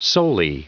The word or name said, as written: soli